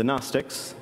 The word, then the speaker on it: Gnostics: The Gnostics, that's G-N-O-S-T-I-C-S with a silent G at the beginning,